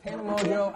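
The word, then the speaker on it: Tana Mongeau: Sitting here with Tana Mojo. I said her name right this time, I think. – I'm proud of you.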